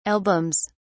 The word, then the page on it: albums: British English